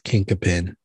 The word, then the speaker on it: chinquapin: So apparently that word is pronounced kinkapin.